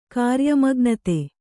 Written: ಕಾರ್ಯಮಗ್ನತೆ

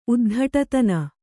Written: ಉದ್ಧಟತನ

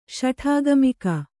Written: ಶಠಾಗಮಿಕ

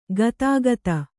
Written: ಗದುಗದ

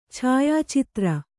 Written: ಛಾಯಾಚಿತ್ರ

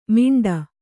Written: ಮಿಂಡ

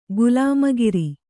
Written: ಗುಲಾಮಗಿರಿ

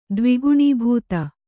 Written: ದ್ವಿಗುಣೀಭೂತ

